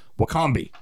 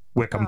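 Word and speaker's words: Wycombe: It's W-Y-C-O-M-B-E, right? So, Wickombe. No. Wickham. Oh. it would be WIC if it's going to be Wickham, but no, not at all.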